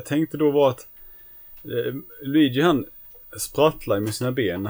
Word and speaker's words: Luigi: Luigi. Ja, hur som helst. Lugi tror jag vi uttalade det förr i tiden. Jag vet inte, vi missade ju en bokstav där men det var ju...